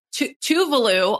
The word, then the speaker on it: Tuvalu: Oh, Tuvalu, also, by the way, I have been saying that wrong. Tovlo or whatever is how I was saying it.